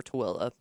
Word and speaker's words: Tooele: I'm guessing the same person is responsible for Tooele.